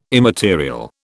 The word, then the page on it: immaterial: British English